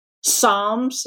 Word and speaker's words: psalms: And it's the end of my day, and this principal comes in, and he hands me a list of psalms. Is that how you pronounce it? – Yeah.